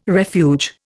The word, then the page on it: refuge: Indian